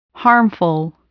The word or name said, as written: harmful